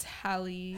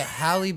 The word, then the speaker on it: Halle: Hailey Bailey and is her name Hailey Bailey? – I think it's Hallie. – Halle Berry. No, but Halle Bailey.